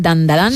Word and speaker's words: Dandadan: Tenemos Dandadan, creo que lo he dicho bien, Dandadan.